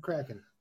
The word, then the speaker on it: Kraken: Is that how you pronounce expansion it? Kraken? Yeah. Kraken? – Kraken.